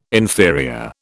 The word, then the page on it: inferior: British English